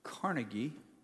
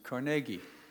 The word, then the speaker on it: Carnegie: I've always heard this name pronounced Carnegie, but apparently in Scotland, where he's from, and in the area of Pennsylvania where their family settled, they pronounce it Carnegie.